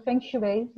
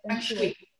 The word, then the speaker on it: Feng Shui: Feng Shui, want zo spreek je dat uit. Feng Shui, zeggen mensen wel. – Dus Feng Shui.